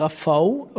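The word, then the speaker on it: Rafał: Ich glaube, richtig heißt er Rafau oder wird so ausgesprochen. Aber ich sage auch Rafal mit doppeltem L, äh, weil wir im Rheinland sind.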